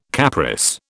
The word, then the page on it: Capris: British English